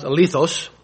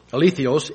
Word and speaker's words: alethinos: It's pronounced alethos, alethios, A-L-E-T-H-I-N-O-S.